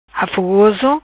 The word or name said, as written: Afofoso